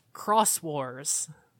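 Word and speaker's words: Xros Wars: And for the record, Cross Wars is spelled with an X. – Where's the X? – In place of the C. – So it's Exos War? – So if you were reading it and you saw it, like me, you would read it as Digimon Exos Wars, but it's pronounced Cross Wars.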